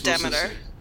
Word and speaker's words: Demeter: I said Demeter. – Okay, Demeter. – Which is also what you said earlier in the podcast. – Yes, I'm just double-checking. – Because that's how they say it in this. And I think it's probably the way you're supposed to say it if you're speaking Greek.